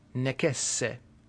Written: necesse

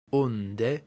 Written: unde

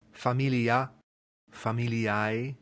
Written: familia

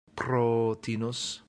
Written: protinus

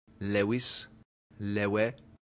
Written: levis

